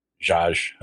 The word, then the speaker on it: Zsasz: Zsasz, I believe is pronounced.